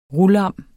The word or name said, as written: rulam